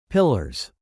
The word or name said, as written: pillars